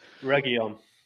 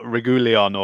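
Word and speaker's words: Reguilón: So how, how, how, do you pronounce this? – Region. Region. Is that how it is? – Not Regulion or whatever.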